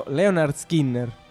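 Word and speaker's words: Lynyrd Skynyrd: Pronounced Leonard Skinner, ovvero Leonard Skinner.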